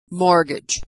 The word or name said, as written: mortgage